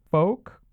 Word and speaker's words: Phoque: He attacked a strip club, the name of which was Club Fuck, but it's spelled P-H-O-Q-U-E, so folk.